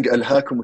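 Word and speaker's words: ألهاكم: And instead of saying Alhaakum At-Takathur, he said Ilahukum At-Takathur. Ilahukum At-Takathur. So someone behind him corrected him. He said Alhaakum.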